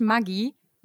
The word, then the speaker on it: Maggi: Maggi, also Julius Maggi, ist Italiener und daher spricht man eigentlich Maggi Maggi aus.